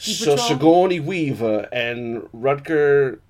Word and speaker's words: Sigourney Weaver: Dolph Lundgren and Sigourney Weaver. – You can't say it like that. – Sigourney Weaver.